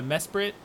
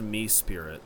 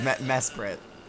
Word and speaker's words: Mesprit: I think it's pronounced Me-Spirit. – Me-Spirit? – No, it absolutely is not pronounced Me-Spirit. – Well, if the professor says it's Me-Spirit, then I guess I'll just have to... – I said it definitely wasn't Me-Spirit.